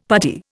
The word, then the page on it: puttee: Indian